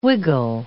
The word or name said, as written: wiggle